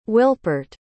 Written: Wilpert

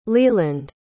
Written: leland

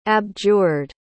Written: abjured

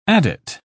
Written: adit

